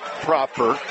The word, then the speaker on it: Proper: To inform our listeners just a bit more about Danville, head coach is a 7-year man, Matt Proper, spelled like proper, pronounced proper.